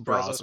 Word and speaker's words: Brazos: So it is at Brazos. Is that how it's pronounced? – Brazos Park. – Brazos. Yeah.